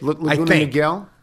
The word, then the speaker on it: Laguna Niguel: Is that how that's pronounced? N-I-G-U-E-L? – Laguna Niguel, I think. – Laguna Niguel.